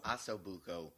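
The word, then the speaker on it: ossobuco: Though uh, th- uh, this feature for the 27th uh, is going to be braised Aco Brusso appetizer. Aco Brusso? – Aso Buco. Aco, Aco what? Aso Buco. Asobuco. Is that how you pronounce it? Good job.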